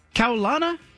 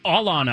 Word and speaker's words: Kaulana: Meanwhile, another defensive player, Kaulana Apelu, am I getting that right? – Alana, I found out. The K is silent.